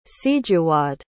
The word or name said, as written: sijuwade